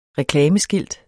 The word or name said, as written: reklameskilt